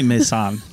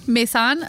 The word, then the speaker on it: Maysan: Oh, Paul's listening to this going, that is not how you pronounce that. – No, it's not how you pronounce it. I'm so sorry, Paul. – Probably Maysan. – Maysan? Okay. Yeah. M-A-Y-S-A-N.